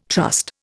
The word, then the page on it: trust: Indian